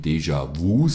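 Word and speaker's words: Déjà Vu: vom Album Deja Vu, so wird es ausgesprochen im Englischen.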